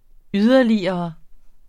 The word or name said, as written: yderligere